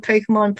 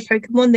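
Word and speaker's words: Pokémon: First of all, every single time they say Pokemon, they pronounce it Pokemon.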